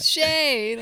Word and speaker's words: Shay: I'll be like, it rhymes with day and lay and way and Shay.